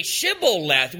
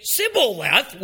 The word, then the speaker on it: Shibboleth: And apparently, the men from Ephraim were not able to pronounce the SH sound. So they would say, Shibboleth, which means a stream. And they would say, "Sibboleth," which means an ear of grain.